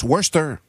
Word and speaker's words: Worcester: ça s'écrit Worcester, mettons, mais ça se prononce Worcester. – Ok. – J'apprends ça, mais il y a une syllabe de trop qui est constante. Tu sais, il prononce un peu comme il est écrit, mais c'est Worcester.